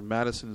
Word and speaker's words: Matuson: Gina Matuson, or Madison is how she pronounces it.